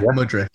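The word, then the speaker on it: Mudryk: Is it Mudrick? Mudrick. – Yeah, Mudrick.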